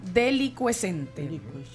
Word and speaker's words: delicuescente: ¿Cuál es la palabra? delicuechente Se escribe Delicuescente, pero con SC.